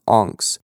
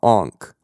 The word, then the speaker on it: Onx: And, by the way, the land is pronounced Ongs, O-N-X, not Onk, like the famous Egyptian totem.